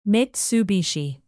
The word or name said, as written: mitsubishi